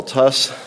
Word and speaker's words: Tuss: For the record, my name is Paul Tuss. That's spelled T-U-S-S.